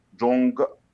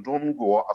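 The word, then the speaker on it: جون جوا (中国): لا تنطق ب يعني لا نقول مثلا جونج جونج يعني آه، جونج جوا مثلا جون جوا الصين لا نقول جونج او جونغ نقول جون بتكون انفيه بعد ان نعم.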